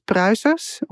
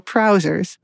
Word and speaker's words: Pruisers: It's Pruisers, which nobody can pronounce here. My husband always says trousers with a P instead of a T, so Pruisers.